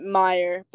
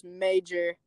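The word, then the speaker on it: Meijer: We're going to go DoorDash after this, but we're sitting at, y'all pronounce it Meyer, but when I first got here, I thought it was Major.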